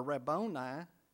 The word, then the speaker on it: Rabboni: The blind man said to him, "Rabboni," or Rabboni is how it's actually pronounced, "Rabboni, that I may receive my sight."